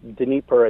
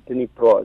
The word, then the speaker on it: Dnipro: Like the Dnipro River, the Dnieper, I think is a lot of ways you'll hear it, but Dnipro is the way they'll pronounce it.